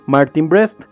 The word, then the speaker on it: Martin Brest: dirigida por Martin Brest, que ahí me, me corregirán la pronunciación historiador, porque yo creo que estoy diciendo como Martin Chichi. no, sí creo que lo estás diciendo bien.